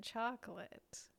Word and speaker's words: Chocolat: They work together to help each other professionally, but things start getting personal when their time together becomes sweeter than chocolate. – That was pretty good. That was pretty good. Main it's note not. Is it's pronounced chocolat, so I think that's the big note.